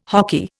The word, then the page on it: hoky: Indian